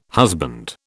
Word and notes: husband: British English

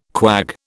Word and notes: quag: British English

